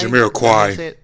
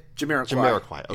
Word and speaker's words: Jamiroquai: We have Kygo. I don't know if I'm saying this right. I never know the pronunciation. Jamiroquai? – Jamiroquai. – Jamiroquai. – Okay. Yeah. Okay, I was close.